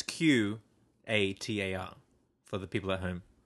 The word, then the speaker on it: Qatar: Guitar? – Yeah. Qatar, but you have an accent, so yours sounds like, to me, it sounds like you're saying guitar. – Well, I hope I'm saying it right.